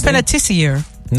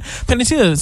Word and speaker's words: Panettiere: About actress Hayden Panettiere, I believe is how you say that. – I thought it was Panettiere. – No, that is not her last name. Her name is Hayden Panettiere.